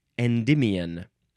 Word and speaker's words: Endymion: Selene loved Endymion.